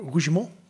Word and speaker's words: Rougemont: Eentje van de propagandachef Jozef Goebbels en eentje van cultuurfilosoof Dennis de Roege, eh, Roegemond. Het zal vast niet zo uitgesproken. – Rougemont? Ja, het is een Zwitser, dus het zal wel op zijn Frans.